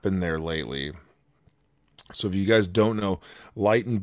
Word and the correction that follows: Lytton: BC, or I'm not sure how to pronounce it. It's Lytton.